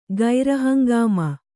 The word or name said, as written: ಗೈರಹಂಗಾಮ